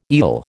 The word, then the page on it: eel: American English